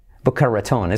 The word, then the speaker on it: Boca Raton: Simplemente Boca Ratón. Suena gracioso. Boca Ratón. Boca Raton, es como español, pero pues como lo típico, ¿no?